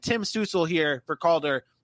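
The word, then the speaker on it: Stützle: So that's pronounced Stutzel, by the way. He's a German. He's a German-born player.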